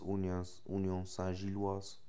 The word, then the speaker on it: Union Saint-Gilloise: Im Großen und Ganzen kann man sagen, dass Union Saint-Gilloise, ich glaube, so spricht man etwas aus, besser gespielt als Fenner.